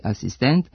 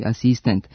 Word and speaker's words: asistent: Zbog toga se oblici sa kratkosilaznim akcentima na poslednjem slogu, dirigent i asistent, akcentuju kao dirigent i asistent sa kratkouzlaznim akcentima na pretposlednjem slogu.